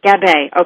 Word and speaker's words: Gabbay: Pamela, do I say your last name Gabbay? – It's Gabbay. – Gabbay. Okay, there we go.